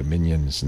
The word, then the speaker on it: Mignon: Mignon's in the studio. Mignon. Like filet mignon.